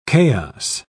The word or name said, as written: chaos